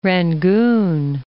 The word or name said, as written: Rangoon